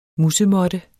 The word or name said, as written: MUS